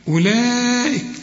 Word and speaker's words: أولئك: لكن الواو اللي مرسوم عليها 5 لا تنطق، شوف أولاء على طول مش تقول أولاء، لا أولئك.